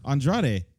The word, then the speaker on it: Andrade: You got to say his name right first before you... – How do you say it then? – Andrade. – Andrade. – Andrade. – Cien Almas. – Well, you were saying Andre for a little bit.